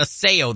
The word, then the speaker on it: Osseo: Yes, I said Osseo. That is the proper Ojibwa pronunciation.